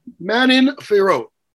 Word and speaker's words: Manon Fierot: And Michael, against that French fighter, let me make sure I can get her name correctly because it's Fierot, I believe is how you say it. What's that? – Manon Fierot. – Manon Fierot, yeah.